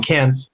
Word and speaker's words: Cairns: I always say it wrong. Cairns.